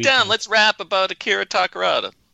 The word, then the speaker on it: Takarada: John, let's talk about Akira Takaruda then a little bit. – Let's say his name correctly, though. Akira Takarada. – Takarada.